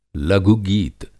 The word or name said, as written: लघु-गीत